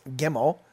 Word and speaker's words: Gimel: Remember, it's a two-syllable word, so it will be pronounced Gimel. The idea is for you to sound out the G sound. Hebrew does not have a J sound like we would have in our culture.